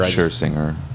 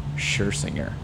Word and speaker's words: Scherzinger: Scherzinger. – Scherzinger.